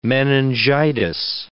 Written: meningitis

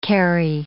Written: Kerry

